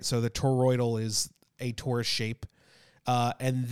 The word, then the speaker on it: toroidal: These new propellers are what's called a toroidal propeller.